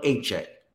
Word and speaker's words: Achane: It's easier than you think. De'Von Achane. Devon A. Chain. A Chain. – A Chain. Uh, a lot of people are like, is it a Kane, a Chane? A Chain.